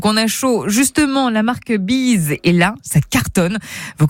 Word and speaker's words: Beez: Bees n'a rien à voir avec la bise. Bees s'écrit B-E-E-Z comme freeze.